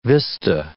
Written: vista

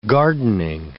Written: gardening

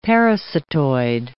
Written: parasitoid

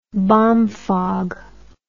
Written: bomfog

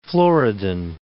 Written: Floridan

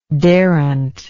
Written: darent

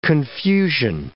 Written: confusion

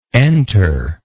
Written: enterer

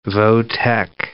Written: vo-tech

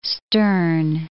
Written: stern

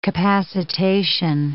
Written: capacitation

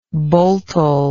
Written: boltel

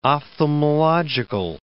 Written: ophthalmological

